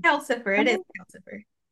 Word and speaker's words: Calcifer: I think I'm mispronouncing it, but it's like Calicifer, something like that. – Calcifer, Calcifer.